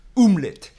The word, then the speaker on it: omelette: 那日本蛋包饭的原型来自于法文的这个英文叫做 omelette，那用法文的发音要讲做 omelette。